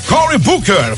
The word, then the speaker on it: Cory Booker: Senador Cory Booker, falei certo, hein, João? – Falou, sim, senhor.